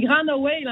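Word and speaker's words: Gráinne Mhaol: Oui, c'est Grand Owl, en fait, c'est en irlandais. Mais bonne prononciation, mais c'est tough à prononcer. L'irlandais, ça ne se prononce pas en tout comment c'est écrit.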